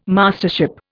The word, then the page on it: Mastership: Indian